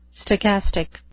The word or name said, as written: stochastic